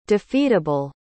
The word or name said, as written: defeatable